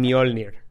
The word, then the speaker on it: Mjolnir: Mira, la neta, yo sé cómo se pronuncia por la película de Thor, güey. Que le dicen Mjolnir.